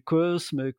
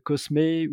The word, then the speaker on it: Cosme: et c'est vrai que la question du cosme, cosmé ou com même se revient très souvent et notre réponse à ça elle est ben personnaliser votre marque aussi, prononcez-le comme vous le voulez. Finalement, euh, nous, on prononce Cosme,